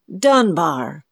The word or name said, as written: dunbar